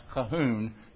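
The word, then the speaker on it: Colquhoun: By the way, if you look at the bottom, his name is pronounced Calhoun, even though it looks different.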